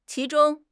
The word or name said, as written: qi2 zhong1